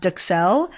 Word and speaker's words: duxelles: Now, excuse me, I don't speak French, but I believe it's pronounced duxelles.